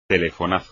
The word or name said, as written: telefonazo